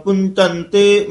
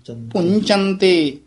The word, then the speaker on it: पुंचंते: पुंचंते जे असी कई बार आम ते पाठी सिंह पुचंते ही कह जांदे हन टिप्पी भी छड जांदे हन अते चचे दी जगह झज्जा ला देंदे हन पुचंते चंते नहीं कहना पुंचंते मही पते महीपते नहीं कहना मही अते पते दे विच डैश दे के लम्मी आवाज नाल बोलना है।